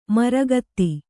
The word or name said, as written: ಮಾರಿಗುತ್ತ